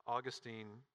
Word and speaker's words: Augustine: Augustine of Hippo, Augustine, most people pronounce it, said this.